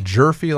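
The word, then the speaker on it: Gyorfi: Is it Gorfie or Gurfy? – Jerfy like Murphy.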